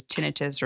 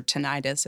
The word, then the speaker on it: tinnitus: And you bring up something that's really typically a sidekick to hearing loss for a lot of people, which is tinnitus or tinnitus. It's pronounced both ways, but kind of a ringing, buzzing, roaring, hissing.